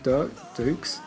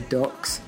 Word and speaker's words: Dux: So Bloodsport is based on the life of martial artist Frank Dukes. When I read it, I kept wanting to say Ducks. – Yeah, because it's written D-U-X, isn't it? – Right.